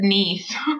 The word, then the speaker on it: Kneese: Everybody always says Kneese. – Well, that's what I said. I was like, okay, let's just see silent. It's K-N-E-E-S-E. So I was like, all right, silent K, but no. – Nope. You pronounce it. Them Germans are hard-headed, I guess.